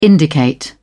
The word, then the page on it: indicate: British English